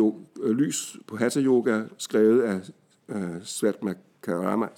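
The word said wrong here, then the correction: Svatmarama: ah, det er ikke udtalt de navne, Svatma Rama.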